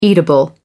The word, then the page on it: eatable: American English